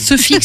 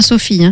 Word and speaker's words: soufie: Soufie, ça s'écrit comment ? – S-O-U-F-I-E. – Ah d'accord. – Voilà. – D'accord. – Pas Sophie, hein, soufie.